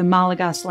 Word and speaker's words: Malagasy: Under Radima I, nobles began to adopt a new Latin alphabet of the Malagasy language. A brief side note, the native language on Madagascar is spelled Malagasy phonetically, but pronounced, to the best of my ability and understanding, Malagas.